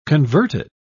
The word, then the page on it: converted: British English